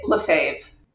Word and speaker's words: Lefebvre: Am I pronouncing that correctly? – No. no. That's okay. – I know it's like one of those French ones It's like, ugh, how do I get through this? – You gotta say the R up in your in your throat. No, you can just say it Lefebvre.